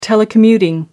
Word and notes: telecommuting: American English

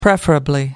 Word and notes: preferably: American English

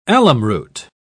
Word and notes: alumroot: British English

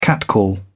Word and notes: catcall: British English

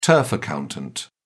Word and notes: turf accountant: British English

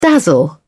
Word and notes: dazzle: British English

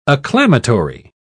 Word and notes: acclamatory: British English

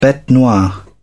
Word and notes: bete noire: British English

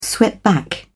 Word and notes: swept back: British English

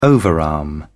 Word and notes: overarm: British English

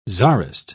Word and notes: czarist: British English

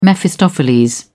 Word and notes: Mephistopheles: British English

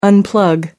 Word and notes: unplug: American English

